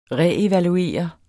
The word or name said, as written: reevaluere